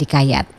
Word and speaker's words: kayat: Dalam dialek Melayu Kuantan, kata hikayat diucapkan menjadi kayat.